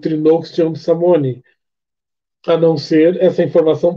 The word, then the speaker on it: Samhain: Inclusive, Samhain é a pronúncia correta em irlandês. Em gaélico escocês seria Samhain, só que com U.